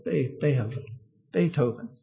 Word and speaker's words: Beethoven: And this man, Beethoven, I said that right. Beethoven. Thank you.